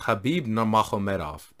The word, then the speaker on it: Khabib Nurmagomedov: Which, by the way, it's Khabib Nurmagomedov. That's actually the correct pronunciation, apparently.